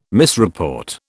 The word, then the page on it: misreport: British English